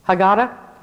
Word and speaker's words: Haggadah: I read a whole Haggadah, is that what they call it? Haggadah? Thank you. My southern pronunciation of Hebrew. I won't even try it again.